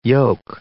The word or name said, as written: yolk